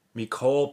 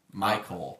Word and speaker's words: Mycole: Mecole Pruitt. – Michael Pruitt. – Oh. – I think. – It's not spelled like Michael. It's spelled like Mycole. – M-Y-C-O-L-E. – I hope he's good just because I want people to struggle with that name.